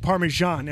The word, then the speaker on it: Parmesan: Like Parmesan ends with an N.